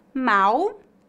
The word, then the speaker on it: mal: No Brasil, a letra L em final de sílabas é pronunciada exatamente como a letra U. Portanto, pronunciamos mal com U e mal com L.